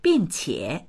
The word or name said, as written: bing4qie3